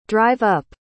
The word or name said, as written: drive-up